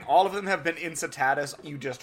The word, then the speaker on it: Incitatus: his his name is pronounced Incitatus. – Incitatus. – Okay. – Yeah. Thanks. Incitatus.